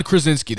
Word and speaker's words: Krasinski: John Krasinski.